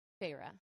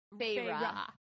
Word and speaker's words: Farah: Farah. – Like Farah Fawcett? – Kind of. More Farah? Like Faye, like fairy. Farah.